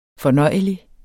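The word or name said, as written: FORNØJELIGERE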